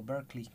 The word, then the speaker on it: Berklee: o Berklee creo que se pronuncia,